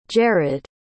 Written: jerod

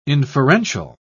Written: inferential

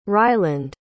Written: ryland

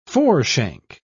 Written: foreshank

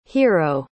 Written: hiro

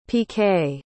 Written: piqué

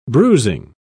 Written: bruising

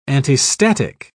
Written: antistatic

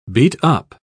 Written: beat-up

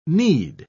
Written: need

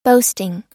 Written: boasting

